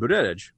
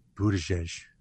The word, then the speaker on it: Buttigieg: and Buttigieg. I'm sorry, Buttigieg, since apparently I pronounce it too hardly for some.